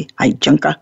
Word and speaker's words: Heijunka: But the Hijunka, which is a Japanese word, it's spelled H-E-I-J-U-N-K-A, Hijunka.